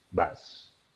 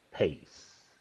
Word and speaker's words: bass: Pero no, el bajo como instrumento no es bass. La pronunciación es como pace. Pace. Repite, pace. The pace is too loud. Recuerda, esto no es pez. Ojo, no es como decir pez.